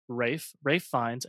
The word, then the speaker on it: Fiennes: I did look up how to pronounce his name, and it's Ralph Fiennes, a.k.a. Voldemort. – It's not Ralph. – No, it's a British pronunciation.